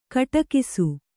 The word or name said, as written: ಘಟಾಕಾಶ